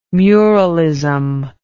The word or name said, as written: muralism